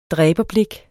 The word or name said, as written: dræberblik